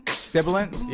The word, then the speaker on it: sibilant: I said it wrong anyway. Cymbalance. – Sibilant? – Yeah, it's like when you make the sound.